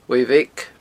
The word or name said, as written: uaimh bheag